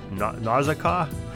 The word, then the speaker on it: Nausicaa: In the Greek epic Odessa, the daughter of King Akinos, Nausicaa, I think is how you say it, Nausicaa, almost like nausea, was found playing a ball game with her servants on the beach.